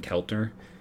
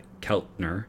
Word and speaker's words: Keltner: And it's, I think, out of five, maybe this will be sacrilegious, I would put Ken Keltner. I think I just butchered that. Keltner, third baseman who was from New Berlin, not far from where I am in Wisconsin.